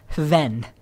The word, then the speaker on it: Hven: Forgive my Danish, but it's Hven. H-V-E-N.